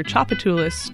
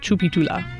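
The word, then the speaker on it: Tchoupitoulas: Chapatulas is pronounced one of four different ways in New Orleans, either Chapatulas, Chapatula, Chapatulas, or Chapatula. Originally, it is almost certainly an indigenous word, though it's spelled using French orthographic rules. And if you were to pronounce it like it were French following those rules, you would pronounce it Chupitula, but nobody does that.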